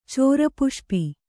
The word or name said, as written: ಚೋರಪುಷ್ಪಿ